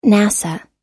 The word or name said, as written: nasa